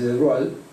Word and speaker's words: de Broglie: Pero nadie había pensado en extender esto a las partículas materiales, hasta que en 1924 el físico francés Louis de Broglie, se escribe de Broglie, pero se dice de Broglie,